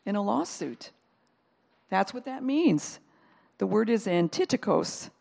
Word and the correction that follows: antidikos: Antidikos, sorry, I said it wrong.